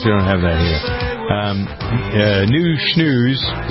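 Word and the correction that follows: Nu Shooz: Is that how you pronounce it? New Shoes? No, New Cheeks. – Shoes. Yeah.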